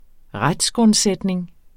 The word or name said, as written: retsgrundsætning